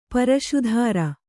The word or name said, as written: ಪ್ರಸ್ತರ